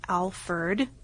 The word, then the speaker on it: Alferd: even though he's generally known as Alferd, like with the E-R-D. – Yeah, E-R-D. Official documents list his name as the more traditional Alferd.